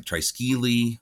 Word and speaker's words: triskele: The most common way is much closer to triskele or triskel. – Triskel. Not that the E is silent. – Right. Yep. That seems to be the way. Um, now, there are other versions that you can find that say like Triskeli or things like that.